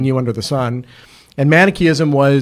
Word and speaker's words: Manichaeism: With Manichaeism.